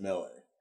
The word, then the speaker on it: Millar: Because I was saying it to Doug at the comic book shop, and they was like, he pronounces it Miller. – I had no idea. – It's Scottish, the A, it sounds like an E.